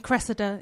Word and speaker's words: Cressida: Cressida is the correct pronunciation. But yeah, it happens a lot.